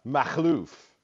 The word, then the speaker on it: Makhlouf: Because but the actual pronunciation of my last name is Makhlouf. That's the actual pronunciation of my name. Anytime you see the KH, that's the sound of phlegm in the Arab language.